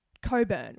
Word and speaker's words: Cockburn: Like, why would you call a town Coburn when it's spelt C-O-C-K-B-U-R-N? – And I've never heard of a silent C-K.